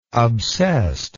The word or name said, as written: obsessed